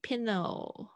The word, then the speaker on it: pillow: 感觉比较像 pillow 哦，pillow low low low 那个 O 要发全，还有最后是毛毯是 blank blanket amazing OK，因为我现在有看到我的学生在在线上，所以我就是也看他们嘴型有没有发出来。